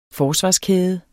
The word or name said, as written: forsvarskæde